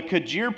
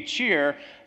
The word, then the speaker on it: Kjaer: My poor daughter, her name Kirsti, is spelled K-J-I-R-S-T-I. So the K-J actually makes a ch sound, right? Except for my last name is spelled K-J-A-E-R, and Americans have butchered it, so we just gone with the Kajir pronunciation, but it's really supposed to be Cheer, so she's going to get her name slaughtered as she grows up, but that's okay.